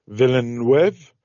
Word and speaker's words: Villeneuve: Dune Part 2 is the second in the series of movies directed and co-written by Denis Villeneuve. – Is how you pronounce his name.